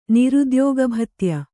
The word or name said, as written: ನಿರುದ್ಯೋಗಭತ್ಯ